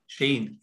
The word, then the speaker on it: Cheyne: It's Sheen, right? – Sheen, yes.